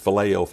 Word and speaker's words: phileo: The Hebrew the Greek, how you pronounce it, would be phileo, phileo.